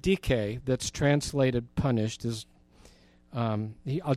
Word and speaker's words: dike: This is the word dike, spelled like dike, D-I-K-E, dike.